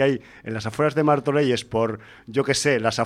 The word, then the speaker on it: Martorelles: Pero tenemos aquí una historia, una peli sobre el narco mexicano y sus secuaces, pero rodada en Martorelles. No sé si lo pronuncio bien, ¿eh? – Sí, está bien.